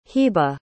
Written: hibah

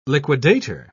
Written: liquidator

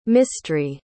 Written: Mistry

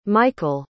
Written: michaele

